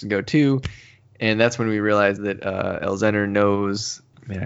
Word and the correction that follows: Elesner: Is it Elzenner or Elesner? – I thought it was Elesner. – So Elesner, and I apologize. I think I called him Elzenner.